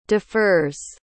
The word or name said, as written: defers